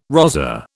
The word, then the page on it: rozzer: British English